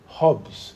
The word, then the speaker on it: Hobbes: Hobbes. Bon, en français, on dit Hobbes, mais je prononce mal, qui est un philosophe du 17e siècle en Angleterre.